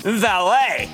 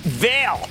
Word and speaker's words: Vale: Valet, often mispronounced Veil.